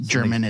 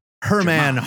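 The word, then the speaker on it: Germán: It's written by Zach Thompson with art by German Garcia. There's an accent over the E. I don't know how to type that. It's not pronounced German. It's Hermann Garcia.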